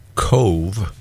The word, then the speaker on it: Cobh: And which is Cove, but it's, believe it or not, spelled C-O-B as in boy, H. And before we got there, how do you pronounce C-O-B-H? And our taxi driver said it's Cove, like C-O-V-E.